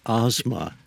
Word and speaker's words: Asma: Stephen Asma. And the proper pronunciation of my name is Asma. But no one will spell it correctly, so we have to say asthma.